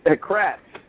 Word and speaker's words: Kratz: Kratz. Yeah, you forgot the R there, but that's okay.